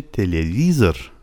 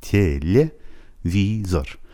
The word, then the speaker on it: телевизор: بعدیش حرف ته روسی و کلمه تلویزیون که به روسی میشه تلویزر.